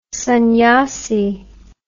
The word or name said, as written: sunnyasee